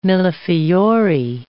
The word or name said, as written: millefiori